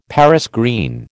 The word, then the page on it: Paris green: American English